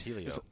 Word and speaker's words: Helio: He was down there when we were recording some of them this time, and I said it wrong when he was sitting there. – It's Helio.